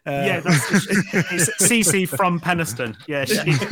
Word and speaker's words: Penistone: But there are a couple of names here for places in Yorkshire, and one of them is Penistone. – Pronounced Peniston, but yes, it is spelt Penistone.